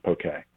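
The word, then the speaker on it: poké: But yeah, traditionally, there is no accent. It's the accent aigu on the E to as a nod to people that it's pronounced poke.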